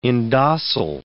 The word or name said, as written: indocile